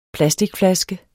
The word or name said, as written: plasticflaske